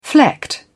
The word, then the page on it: flecked: British English